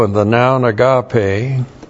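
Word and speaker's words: agape (ἀγάπη): The noun that comes from that is agape, A-G-A-P, long E, that's the noun love, but this is in the Epistle of John.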